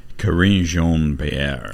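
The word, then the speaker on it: Karine Jean-Pierre: How do you pronounce her name? Smash? – Yeah. – Corinne Jean-Pierre. – Yeah. Yeah, there you go.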